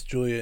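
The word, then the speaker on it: Giulia: Because I'm pretty sure it's Julia in Italian.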